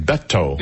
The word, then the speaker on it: Beto: But anyway, he pronounces his name Beto. – Mm-hmm. – He doesn't say Beto or Beto. He says Beto. That's how he says his name.